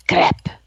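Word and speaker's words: crêpe: and how do you pronounce it? And he said, crepe.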